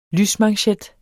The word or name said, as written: lysmanchet